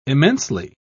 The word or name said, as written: immensely